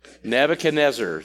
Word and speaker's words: Nebuchadnezzar: Nebuchadnezzar.